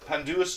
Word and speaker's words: pandanus: How do you say that? Pandanus trees? – Pandanus trees.